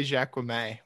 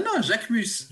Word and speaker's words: Jacquemus: Okay, we still have never pronounced it correctly, apparently. We used to say Jacquemus. We thought it was Jacquemus. – No, Jacquemus. Jacquemus. – Jacquemus. – Jacquemus. – Okay.